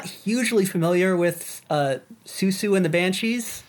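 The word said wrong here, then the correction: Siouxsie: Am I saying the name right? – No. Susie. – Susie. Is it just Susie?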